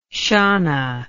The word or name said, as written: shanna